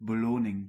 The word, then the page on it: beloning: [bə.lo.niŋ]